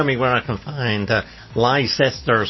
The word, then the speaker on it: Leicester: And the thing is, they're quite right. The pronunciation is right. If you pronounce it as it's written, uh-huh. – it's Leicester Square. But we're just slurring. Leicester. Because we're drunk most of the time.